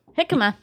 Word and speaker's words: jicama: Jicama is also, just for those who are like trying to write stuff down, jicama is actually not spelled the way it sounds. – Not at all. – It's spelled J-I-C-A-M-A.